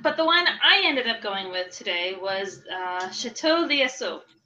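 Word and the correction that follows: L'Oiseau: Did I say that right, Michelle? – Let me see. – I'm trying to learn French. It's spelled L and then a little apostrophe O-I-S-E-A-U. – Chateau Loiseau. – Loiseau. Okay, I was close.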